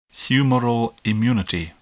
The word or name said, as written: humoral immunity